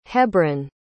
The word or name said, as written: hebron